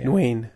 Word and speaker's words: Nguyen: James Nguyen or something like that, yeah. – Nguyen, okay. – Nguyen's how you say it.